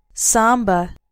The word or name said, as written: samba